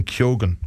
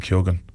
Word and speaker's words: Keoghan: They kept saying Keoghan, right? Mm. I heard Barry Keoghan saying Keoghan last week. So I'm saying Keoghan now. If he's, I mean, whatever he says goes, obviously.